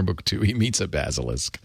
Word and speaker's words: Basilisk: It's a legendary reptile reputed to kill with a glance, pronounced Basilisk.